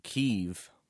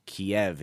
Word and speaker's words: Kyiv: Well, from what I understand, Kyiv is the Ukrainian pronunciation. Kiev is the Russian pronunciation from USSR.